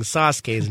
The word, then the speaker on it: Sasuke: tell us that we're mispronouncing Sasuke's name. And he's like, it's Sasuke.